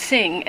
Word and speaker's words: Sing: My name is Sing Liu. The first name, by the way, is pronounced Sing as in S-I-N-G, just so you know.